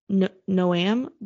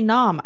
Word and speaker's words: Noam: I can't even pronounce Noam. Wow. I don't understand. N-O-A-M. N-O-A-M. Noam? – Noam? Noam? I don't know, man. – I don't know. My brain saw that and wanted to say Nom. I know that's not right.